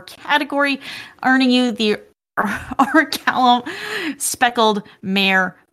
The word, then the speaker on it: Orichalcum: Some people pronounce it Orichalcum, but it's Orichalcum.